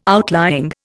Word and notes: outlying: Indian

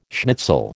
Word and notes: schnitzel: American English

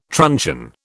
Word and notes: truncheon: British English

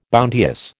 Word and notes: bounteous: American English

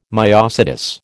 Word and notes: myosotis: American English

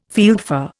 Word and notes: fieldfare: Indian

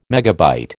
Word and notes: MB: American English

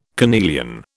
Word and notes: carnelian: British English

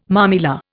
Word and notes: mamilla: Indian

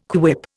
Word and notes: quip: Indian

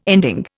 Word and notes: ending: Indian

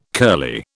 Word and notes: curly: British English

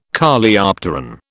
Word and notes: Coleopteron: British English

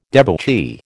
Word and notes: debauchee: American English

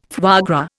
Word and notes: foie gras: Indian

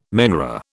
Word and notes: menorah: British English

